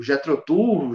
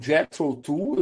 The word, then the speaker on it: Jethro Tull: O que, que tem a ver, sei lá, o, o Jetrotu, o Jetrotal, não sei o que o cara não pronuncia do jeito aí, com o Tangerine Dream? Sim, nada. Nada, velho. É tudo Nada. prog. É, o, o Jethro Tull, eu, eu falo Jethro Tull também.